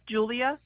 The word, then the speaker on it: Julia: Yep, it's Julia.